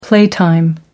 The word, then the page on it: playtime: American English